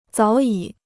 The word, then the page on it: 早已: zǎo yǐ